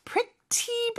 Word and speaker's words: pretty: 예를 들어서 pretty busy 뭐 이런 식으로 하는 경우도 있는데 일반적으로는 요 T 발음은 모음 소리 사이에 껴서 real처럼 pretty 이렇게 발음이 됩니다.